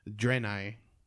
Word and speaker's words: Draenei: Draenei is how you pronounce it.